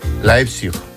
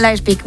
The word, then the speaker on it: Leipzig: Leipzig. ¿Lo he dicho bien? – Qué importante es saber idiomas. – Tengo aquí a mi amigo que me lo dice. ¿Cómo se dice? – Leipzig. – Leipzig. Leipzig, ¿ves? – Leipzig, muy bien.